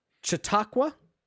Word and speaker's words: Chautauqua: Um, Chautauqua, okay, that one I know.